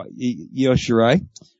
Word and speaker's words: Io Shirai: Io Shirai? – There you go. Io Shirai.